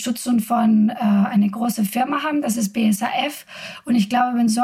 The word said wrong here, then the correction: BASF: BASF, äh, glaube ich. – BSAF, habe ich das falsch gesagt.